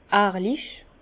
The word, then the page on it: ehrlich: Bas-Rhin; Herrlisheim